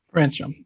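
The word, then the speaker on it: Brännström: Brannstrom, yeah.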